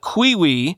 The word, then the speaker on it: Qiui: Quote, UK-based security firm Pentest Partners said the flaw in the Qiwi, that's spelled Q-I-U-I,